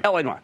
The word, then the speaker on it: L.A. Noire: But there's this L.A. Noire okay? And, and I hope I'm pronouncing it right. L.A. Noire, right. L.A. Noire. Yeah.